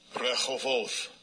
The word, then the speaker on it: Rehoboth: And he said, for now the Lord, I'm going to say it the way it's supposed to be said. – Rehoboth.